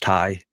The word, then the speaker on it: Tay: So then we go to Tay Mello, Ty, I guess Ty Mello versus Toni Storm. I know her name is pronounced Ty, but because she's going to be teaming up again with Anna Jay, she should just be like, yeah, pronounce it Tay.